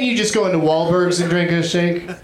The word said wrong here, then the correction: Wahlburgers: I mean Wahlburgers.